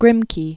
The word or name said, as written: Grimke